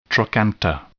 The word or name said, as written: trochanter